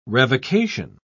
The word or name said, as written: revocation